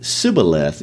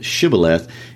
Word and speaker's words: Shibboleth: They knew Ephraimites couldn't pronounce the SH sound, so they would pronounce it Sibboleth instead of Shibboleth.